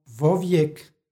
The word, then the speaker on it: vovijek: Pošto imamo predlog koji počinje glasom v i sljedeću reč, odnosno imenicu koja počinje istim glasom, između njih se dodaje radi lakšeg izgovora i preciznijeg značenja izgovorno o, dakle vovijek.